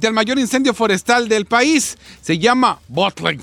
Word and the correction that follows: Bootleg: Fire. Ay, a ver, ¿sí me lo entendí? No, no. Fire. ¿cómo era? Bootleg Fire. ¿Bootleg? Bootleg Fire. ¿Cómo se escribe? B-O-O-T-L-E-G.